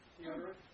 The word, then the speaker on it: Theodoret: Theodoret. – Yeah, Theodoret. Say it fast and you lose all the syllables. That's good. That's like I say Louisville.